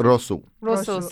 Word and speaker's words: rosół: This is chudnik. Is that how you pronounce it? – Rosół.